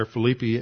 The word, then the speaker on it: Philippi: Philippi or Philippi, as it's pronounced in Greek, and that it was a colony, and he attached his name to that colony.